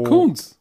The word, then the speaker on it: Koonce: K-O-O-N-C-E. Wie spricht man das aus? Co Coons. Coons, okay. Coons. So, Malcolm Coons. Doppel-O ist doch U in Englisch.